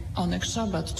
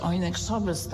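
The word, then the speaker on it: Oneg Szabat: jakim było Onek Szabat, czy Onek Szabes, tak jak to wymawiali ludzie.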